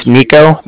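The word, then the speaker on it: Niko: My name is Nico. That's N-I-K-O.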